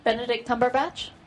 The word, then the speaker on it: Benedict Cumberbatch: Benedict Cumberbatch? – That's the one.